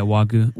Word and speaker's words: Wagyu: It was like Wagyu. Is that how you say it? – Yeah, Wagyu.